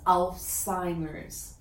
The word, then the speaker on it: Alzheimer's: All-timers. – You know, it's Alzheimer's.